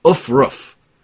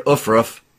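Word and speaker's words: aufruf: And the word of the day is ufruf. And if you need to spell that, it's A-U-F-R-U-F. All right, let's try it, everybody. Let's all say the word ufruf.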